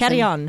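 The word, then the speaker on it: Carrión: And then I don't remember the last Carrion. – Name. Carrion.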